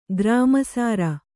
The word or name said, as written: ಗ್ರಾಮಸಾರ